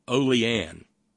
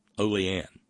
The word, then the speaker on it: Olean: It's not Olean. It looks like Olean. It's O-L-E-A-N. Kind of seems like a brand of cooking oil or bacon or something, but it's Olean. Olean. Accent on the first syllable.